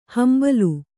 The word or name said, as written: ಹಂಬಲು